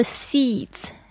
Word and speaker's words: seeds: seeds 种子 S-E-E-D-S 特别注意一下 D-S 的发音，像子子子的声音，像孩子的子那个声音 Seeds You can grow flowers Grow flowers 就是种花 G-R-O-W Grow flowers F-L-O-W-E-R-S Flowers Or you can grow weeds Weeds 就是野草，就是那种杂草，就可以说 weeds W-E-E-D-S, weeds.